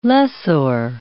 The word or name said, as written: lessor